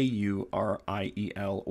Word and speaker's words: Uriel: Uriel is spelled A U R I E L or U R I E L. Uh, pronounced Uriel, and it means shining light of God. stems from the words Ur uh, and El.